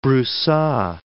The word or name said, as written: Brusa